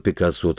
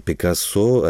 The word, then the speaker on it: Пикассо: Ну, и с Пабло Пикассо, так вспомнить, художники они на слуху. Потому что для нас он Пикассо, а в Италии он исключительно Пикассо, и, так сказать, его по-испански произносят.